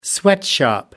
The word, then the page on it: sweatshop: American English